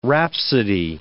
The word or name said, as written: rhapsody